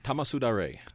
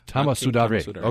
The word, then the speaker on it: Tamasudare: inspired by the ancient Japanese feat of Nanking Tamasudari. And I don't know if I'm pronouncing that correctly. – Tamasudare. – Tamasudare. Okay.